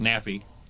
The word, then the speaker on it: NAFI: Well, I'm going to correct the pronunciation first. NAFI.